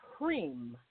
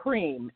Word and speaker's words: HREEM: And the mantra for this week is HREEM. I'll say it one more time. It's HREEM. It's H-R-E-E-M. That's how you pronounce it, HREEM.